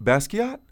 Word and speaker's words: Basquiat: Wait, Basquiat? – Yeah. – That's how it's spelled when you sent it to me. I didn't know that's how it was pronounced.